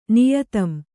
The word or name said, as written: ನಿಯತಂ